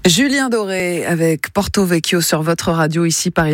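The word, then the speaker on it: Porto-Vecchio: Voilà, apparemment moi non plus, parce que notre standardiste Saveria, d'origine corse, vient de me reprendre sur la prononciation. Alors il faut dire Porto Vecchio.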